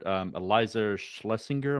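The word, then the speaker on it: Iliza Shlesinger: She told me she discovered Eliza Schlesinger. Am I saying her name right? – Yeah.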